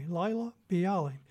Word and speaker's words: Laila Biali: It's spelled L-A-I-L-A-B-I-A-L-I, Laila Bialy.